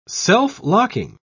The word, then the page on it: self-locking: British English